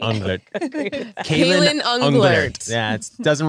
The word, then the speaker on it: Unglert: Unglert.